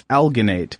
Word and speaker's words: alginate: Alginate, I'm assuming that's a hard G, not alginate, but it's alginate, which is a biomaterial found in the cell walls of brown algae seaweed.